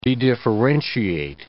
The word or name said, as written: dedifferentiate